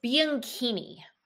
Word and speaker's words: Bianchini: It is Bianchini. – Bianchini. – So the C-H is a K sound because it's Italian.